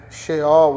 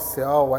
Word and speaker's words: Sheol: A palavra inferno, talvez aí no Antigo Testamento, seria melhor é, Sheol ou Seol, aí depende da pronúncia que alguém quer usar, mas o Seol ou Sheol é nada mais que o mundo dos mortos, o ambiente dos mortos.